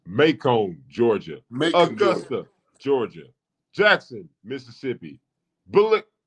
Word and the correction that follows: Biloxi: How do you say that, Coach? Billux. Biloxi. Yeah. Biloxi. Thank you.